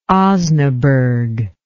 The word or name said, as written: osnaburg